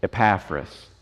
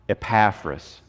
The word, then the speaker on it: Epaphras: Epaphras. So one more time, Epaphras.